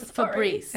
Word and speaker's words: Fabrice: His name was Fabrice. – F-A-B-R-I-C-E. Like Fab Rice.